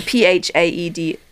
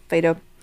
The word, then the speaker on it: Phaedo: It's the Phaedo. – FIFA. – Phaedo.